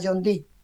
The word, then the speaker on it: John Dee: Según una nota hallada que había llegado a Inglaterra en el siglo XVI, este espejo había pertenecido al célebre matemático y ocultista John Dee.